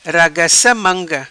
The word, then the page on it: Ragaca Manga: patua